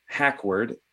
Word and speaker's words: Hacquard: So I'm Darren, D-A-R-R-I-N, Hackward, H-A-C-Q-U-A-R-D.